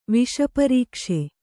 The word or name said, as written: ವಿಷಪರೀಕ್ಷೆ